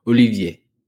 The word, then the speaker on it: Olivier: For Olivier's credit, you keep saying his name wrong. You do it every show. You see, Olivier. There's an I after the V? – Olivier. – Like Giroud. Olivier.